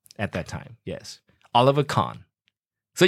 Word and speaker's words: Kahn: And you know, Carmen, there is a goalkeeper called Carmen. – Oliver Kahn. – Oh, it's not Carmen. It's a Kahn. – Oliver Kahn. – Oh, that's a German yes. pronunciation, Kahn, not Carmen.